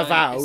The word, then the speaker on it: Avowed: You know that game called, if I pronounced it correctly, Avowed? Is, you know, the one that's by Avowed.